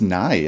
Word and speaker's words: Nighy: Slarty Bart Fast, by the way, is Bill Nighy. – I think it's Nye, actually. Nye? I'm pretty sure it's just pronounced that. So he's pronounced like Bill Nye.